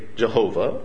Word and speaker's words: Yahweh: Lord, all caps, is the most significant name for God in the Old Testament and likely is pronounced Yahweh. Some will say Jehovah, but I prefer Yahweh.